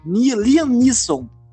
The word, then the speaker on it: Liam Neeson: Liam Neeson não, é mais ou menos assim que pronuncia.